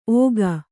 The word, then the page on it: ಓಗ: Kannada